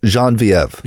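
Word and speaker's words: Geneviève: Jean-Vieve. – Oh, that is that how you say it? I could never say her name right.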